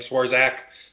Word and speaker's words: Swarzak: Cordell actually got us Anthony Suarez, or however you say his name, Swarzak, sorry, back in, I believe that was 2017.